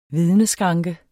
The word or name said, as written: vidneskranke